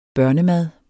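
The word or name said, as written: BØRNEMAD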